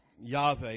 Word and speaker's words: Yahweh: The word Jehovah, Yahweh is probably how you really should pronounce it, but we'll say Jehovah.